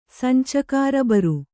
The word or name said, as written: ಸಂಚಕಾರಬರು